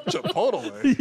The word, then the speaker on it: Chipotle: Just light on the Chipotle. – Bagel, toast it, you know. – You pronounced every letter of Chipotle, man. Chipotle. Oh, man.